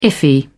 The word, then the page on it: iffy: British English